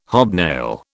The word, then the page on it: hobnail: British English